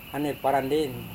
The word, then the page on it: hane-paranden: Serewen